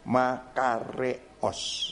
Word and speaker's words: makarios: Makarios itu Makarios kalau tulisnya ya. Makarios tapi bacanya makareos.